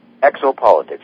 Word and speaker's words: Exopolitics: Are you going to tell us what Exopolitics, did I pronounce that right? Exopolitics, yeah.